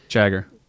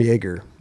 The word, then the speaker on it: Jagger: Jagger. – I call him Jaeger. I thought the J is silent. – It is Jagger, right? – It is Jagger.